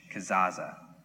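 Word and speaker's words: Kezazah: It's K-E-Z-A-Z-A-H, Kazaza.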